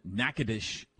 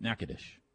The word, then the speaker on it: Natchitoches: And the one in Texas is pronounced Nacogdoches, and the one in Louisiana is pronounced Natchitoches. Pretty sure that's right.